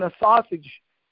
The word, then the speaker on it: assuage: And they, am I pronouncing that right? A sausage? Help me on this. – I would say assuage.